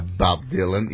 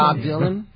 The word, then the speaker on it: Bob Dylan: Mire, pues tenemos a Bob Dylan y así lo pronuncio. – Bob Dylan.